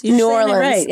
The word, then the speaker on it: New Orleans: Am I saying it right? Is it like New Orleans or New Orleans? – New Orleans. – New Orleans. Okay. – Bravo.